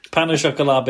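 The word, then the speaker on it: pain au chocolat: Pan au chocolat being pronounced perfectly was yeah.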